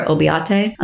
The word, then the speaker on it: Oboite: Deontay Reynolds and Stephen and you'll hear his name pronounced two ways. It's either Obote or Obiate.